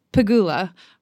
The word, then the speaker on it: Pegula: It's Pegula, according to Jessica on her pronunciation on the WTA website.